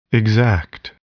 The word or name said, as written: exact